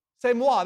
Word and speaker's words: C'est moi: Well, Dad, how do you pronounce that? C'est moi? What is it? What is it? C'est moi, that's it.